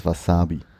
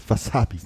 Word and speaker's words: Wasabi: Das Wort hat nicht andere äh, Betonung wie Wasabi oder irgendwie so, sondern es ist... Äh, was Wasabi. Och, es kommt auch auf den Dialekt innerhalb Japans genau. an. Aber ich glaube, die erste Silbe wird eigentlich nicht betont.